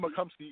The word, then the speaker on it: McComsey: Tim McComsey, yeah.